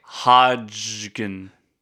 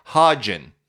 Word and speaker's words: Hodgen: You have to pronounce the D. – It's Hodgkin. H-O-D-G-E-N. Hodgkin. Like a Hodge. Like a Lodge. Hodgkin. There's no K. You can't say Hodgkin. – Hold on. – Because you're pronouncing the D and the G, and then you're also adding another syllable. So Hodge is correct. Hodgin. – It's Hodgenville? – Yeah. – H-O-D-G-E-N-V-I-L-L-E? – Correct.